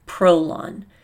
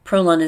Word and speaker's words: Prolon: And Walter Longo created Prolon. Prolon is spelled P-R-O-L-O-N.